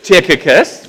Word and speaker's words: Tychicus: And so he says there, of our friend Tychicus, say his name again, Tychicus, we got it.